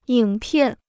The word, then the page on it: 影片: yǐng piàn